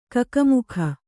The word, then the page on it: ಕಕಮಕ: kannada